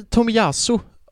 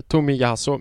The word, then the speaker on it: Tomiyasu: Jag tycker det är inte Tomiyasu har jag lärt mig uttala det. Tomiyasu. Men anyhow.